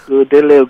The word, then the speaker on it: Oladele: Everyone calls me Deli, so that's Oladele Ogunseitan.